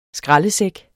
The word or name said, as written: skraldesæk